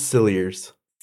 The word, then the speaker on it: Cilliers: It's Cilliers. – Cilliers? Okay. – Well, that's how the documentary pronounced it. Other places I saw pronounced it Cilliers, but I'm going to go with Cilliers because...